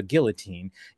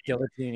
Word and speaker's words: guillotine: Did you pronounce it guillotine? – Yeah, guillotine. Yeah. – Yeah. The French way is guillotine.